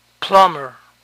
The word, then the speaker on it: plumber: Plumber. Plumber. Noten que la B aquí no se pronuncia.